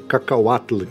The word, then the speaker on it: cacahuatl: Olha aí a pronúncia, cacauatl, que é uma palavra cacauatl.